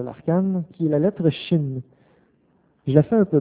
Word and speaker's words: Shin: Son point est inscrit au milieu. Se prononce Shin. Et cette lettre-là se continue de se prononcer Shin tant que le point est inscrit au milieu. Aussitôt que le point est envoyé à gauche, le H disparaît et se prononce Sin, soit mot avec lequel les Anglais ont fait le mal, le péché.